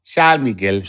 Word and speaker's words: Samilgil: Samilgil.